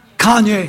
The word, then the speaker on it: Kanye: What's the right pronunciation? – Kanye. – Kanye. Is that right? – Yeah.